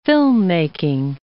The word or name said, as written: filmmaking